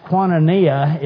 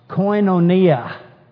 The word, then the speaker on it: koinonia: And really, instead of being pronounced Quantania, it probably should be pronounced koinonia.